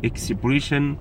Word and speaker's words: expression: expression among people، expression تعبير، expression ال S-S-I-O-N بتتنطق شين، expression، ها بيحصل فيها ايه؟